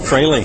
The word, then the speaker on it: Fraley: Fraley. – It is like Fraley. Staley.